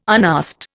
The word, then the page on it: unasked: Indian